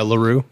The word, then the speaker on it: La Roux: How do you say La Roux? Is that how you say? Oh yeah, La Roux.